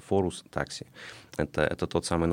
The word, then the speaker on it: Forus: Форус, мы произносим его Форус, но внутри там зашифровано For Us, или с английского для нас.